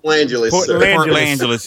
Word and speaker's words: Port Angeles: Port L.A. Angelos, or however it's pronounced. – Port Angeles.